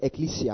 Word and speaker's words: ekklesia: It's the Greek word ekklesia. I'll spell it for you. E-K-K-L-E-S-I-A. E-K-K-L-E-S-I-A.